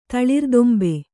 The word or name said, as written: ತಳಿರ್ದೊಂಬೆ